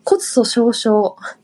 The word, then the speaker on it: 骨粗鬆症: あ、骨粗鬆症。